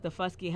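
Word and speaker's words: Daufuskie: I think it's Dufusky, how they pronounce it colloquially.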